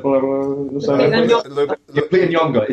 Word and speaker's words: Lupita Nyong'o: Lupita Nyong'o, is it? – Yeah. – Fuck, thank Christ, because I thought I was going to brutalise that. – I think it is, isn't it? – Lupita Nyong'o, yeah.